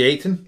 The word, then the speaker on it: Ayton: I did question the play of, I don't know if I pronounced his name right, Ayotel or DeAndre Ayton? – Ayton. – DeAndre Ayton, sure.